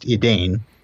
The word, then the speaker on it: Edain: That's E D A I N, but not pronounced Edain.